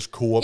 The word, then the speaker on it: Coop: Øh, det har jeg også godt hørt andre sige, men jeg er altså overbevist om, at det udtales Coop. – Ja, ja, du var bare, hvis nu der var nogen, der sad ligesom mig og kalder det Coop, så skulle I bare vide, at jeg har også.